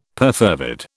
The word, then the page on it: perfervid: British English